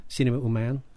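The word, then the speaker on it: Cinéma Humain: I want to get a little further into your organization, which we English speakers would pronounce Cinema Humain. Mm-hmm. Cinema Humain? – Yeah, that's right.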